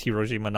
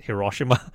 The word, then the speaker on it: Hiroshima: It's pronounced Hiroshima, not Hiroshima.